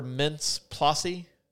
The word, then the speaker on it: Mintz-Plasse: This stars Jonah Hill, Michael Cera, Emma Stone, Christopher Mintz-Plasse.